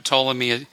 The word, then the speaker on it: Ptolemy: You know, Ptolemy is spelled with a silent p, and and trap is spelled with a silent p.